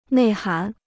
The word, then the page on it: 内涵: nèi hán